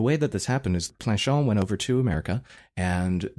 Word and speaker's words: Planchon: Planchon. – Planchon.